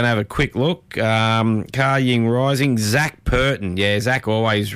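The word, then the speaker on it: Kaying Rising: If you're wondering how it's pronounced, it's spelled Car Ying Rising, so K A, then Y I N G, then Rising, so Car Ying Rising.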